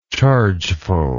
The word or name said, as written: chargeful